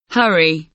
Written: hurry

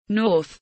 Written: north

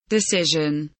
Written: decision

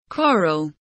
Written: quarrel

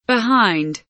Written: behind

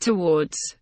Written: towards

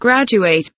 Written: graduate

